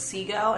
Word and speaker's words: Sego: For those of you that are going to look this up at some point, it's Seago, and it's pronounced spelled S-E-G-O.